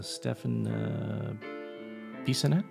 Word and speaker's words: Stefan Bissonnette: The article was written by Stefan Bissonnette. Am I saying that right? Yeah.